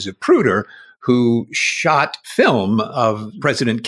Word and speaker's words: Zapruder: Since your very first episode, I've given your shows all the lackadaisical attention of a Zapruder film. – Zapruder. – Okay, you say Zapruder, I say Zapruder, mainly because I said it wrong. But actually, you're right, it's a Zapruder film.